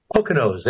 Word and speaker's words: Poconos: Did I say that right? Poconos, there we go.